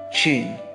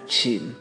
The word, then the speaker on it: 请: The word for please is qin. Think of it as a C-H-I-N. Qin. Or actually C-H-E-E-N would probably get a better, more correct sound. That's not how it's spelled, but that's like, if we want to spell it how it sounds, it would be C-H-E-E-N, qin.